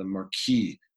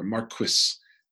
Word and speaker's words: Marquis: So almost immediately, Charles II takes the throne and he martyrs the man who enthroned him as king, the Marquis or Marquis, as they pronounce it, of Argyll, the first martyr of the covenanting cause, whose dying words were, "I set the crown on the king's head.